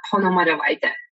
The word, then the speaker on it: Ponomariovaite: I always leave my last name on the side, which is very complicated. It should be pronounced as a Ponomariovaite.